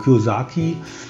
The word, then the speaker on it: Kiyosaki: Es ist auf jeden Fall ein sehr ähm, lesenswertes Buch von Herrn Kiyosaki heißt der, ne? so wird er ausgesprochen, Kiyosaki. Ich glaube hm.